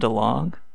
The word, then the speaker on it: DeLonge: Tom DeLonge? – Yeah.